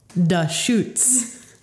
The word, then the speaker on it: Deschutes: Now I look at it, the main cover of it, Da Schutz, is spelled D-A space S-H-O-O-T-Z with an exclamation point. But the actual company is spelled D-E-S-C-H-U-T-E-S. – I picked that one because it has a nice blurb on the back about how no one could pronounce their name.